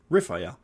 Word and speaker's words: Raphael: So, Raphael, pronounced Raphael, uh, literally translates to healing, which is of God.